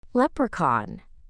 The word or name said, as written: leprechaun